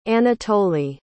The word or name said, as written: Anatoli